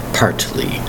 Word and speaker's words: partly: The word is partly, P-A-R-T-L-Y. Remember to pronounce that T hard, partly.